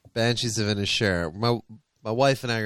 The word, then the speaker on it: Inisherin: That Banshees of Inisherin. – Banshees of Inisherin, mate.